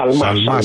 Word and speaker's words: Σαλμά: Να πούμε πολύ γρήγορα και για την άρση ασυλίας του κυρίου Σιαλμά, έτσι δεν είναι? – Σαλμά, συγγνώμη για το γιώτα που εμφυλοφόρησε στην προφορά μου.